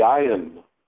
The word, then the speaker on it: ayin: And we are taught in America that the ayin has no sound, just like the aleph. And just like the hey, when it's at the end of the word, is not pronounced. The ayin has no sound. But again, it's not quite true. It's a difficult sound to make, because it's at the back of the throat.